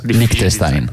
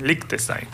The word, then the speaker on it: Liechtenstein: Liechtenstein. – Liechtenstein.